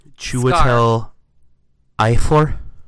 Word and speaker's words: Chiwetel Ejiofor: Oh, and then this guy, I can never pronounce his name, is playing Chiwetel Ejiofor.